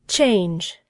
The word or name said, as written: change